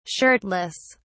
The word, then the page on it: shirtless: British English